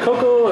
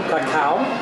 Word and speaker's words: cacao: Yes, the cocoa is, we pronounce it here in Mexico, cacao.